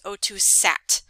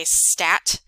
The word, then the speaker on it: O2 sat: Um, I hear a lot of students and, and even other fellow nurses say stat. Um, so you want to make sure that you're saying it correctly. So it's O2 sat saturation. We're